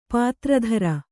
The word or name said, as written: ಪಾತ್ರಧರ